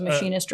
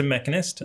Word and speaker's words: mechanist: Is how it's pronounced. Wasn't too sure if it was a machinist or not. – It's a mechanist.